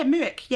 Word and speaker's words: Muick: Yeah, Muick.